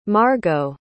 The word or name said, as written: margaux